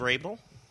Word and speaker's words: Raebel: Oh, by the way, my special co-host, I want to thank you for joining us, Steve Rabel.